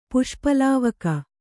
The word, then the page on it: ಪುಷ್ಪಲಾವಕ: Kannada